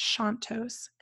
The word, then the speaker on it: Chontos: I was calling her Heather Chantos, and I learned how to pronounce her name on this trip. It's Chantos, and it's Heather, C H O N T O S dot com.